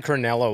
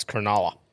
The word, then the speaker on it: Cronulla: Was I the one who was saying it wrong that whole time? Cronulla. I was saying Cronulla, wasn't I? And they were like, it's Cronulla.